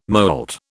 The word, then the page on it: moult: British English